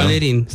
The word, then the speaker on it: Valerine: Pentru că săptămâna trecută au fost invitați cei de la Valerine, nu știu cum se pronunță în română. – Valerin sau Valerine. – Valerin, așa.